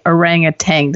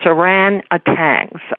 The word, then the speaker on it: orangutan: Now, with this, and this is just playfulness, but people always mispronounce and say orangutan. Does that just put the hair up on your arm? – No, I had so much trouble learning to pronounce it anyway. It's without the G. It's orangutans.